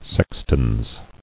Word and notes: Sextans: Sex·tans

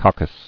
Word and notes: Coccus: coc·cus

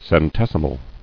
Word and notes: Centesimal: cen·tes·i·mal

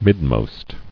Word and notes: Midmost: mid·most

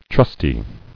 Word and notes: Trustily: Trust"i*ly

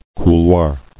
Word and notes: Couloir: cou·loir

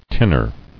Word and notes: Tinner: tin·ner

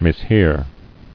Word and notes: Mishear: mis·hear

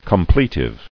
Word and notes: Completive: com·ple·tive

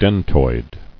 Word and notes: Dentoid: den·toid

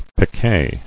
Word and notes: Picquet: pic·quet

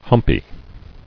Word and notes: Humpy: hump·y

